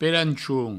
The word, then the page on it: pelanchon: pelanchoun; provencal